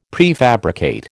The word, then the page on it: prefabricate: American English